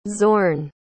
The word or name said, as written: zorn